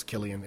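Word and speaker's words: Cillian: I actually saw him interviewed, and he did confirm it was Killian. It's Celtic.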